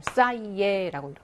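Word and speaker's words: ça y est: 그래서 좋아, 됐다 뭐라고 할때 싸이에라는 표현인데 이것도 이렇게 쓰, 이, 에 이렇게 하지 않는다는 거예요. 이거는 모음과 모음과 모음의 발음이 다 겹치잖아요. 그러니까 너무 이렇게 발음이 특색이 없다고 해야 되냐? 그냥 이렇게 스무스하게 쑥 넘어가니까 얘를 조금 바꿔주는 거예요. 싸이에라고 이렇게 쓰이에 안 하고요.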